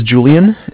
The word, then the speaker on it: Julian: My name is Julian. It's J-U-L-I-A-N. – Okay. – And I'm calling from Bank of Cardiff. It's spelled C-A-R-D as in dog, I-F-F as in Frank.